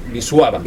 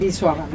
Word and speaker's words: Wisława: Bueno, Escribes y Vendrás, de Kornel Filipovich y Wisława, que gracias a Teresa, ahora sabemos que no es Wisława, sino Wisława. – Wisława.